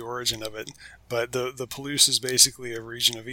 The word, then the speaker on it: Palouse: Palouse, I said it right? – Palouse.